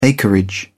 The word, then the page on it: acreage: British English